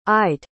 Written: i'd

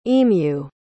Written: emu

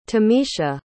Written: tamisha

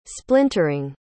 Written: splintering

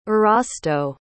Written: erasto

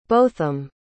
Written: botham

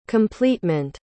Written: completement

